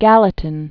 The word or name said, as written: Gallatin River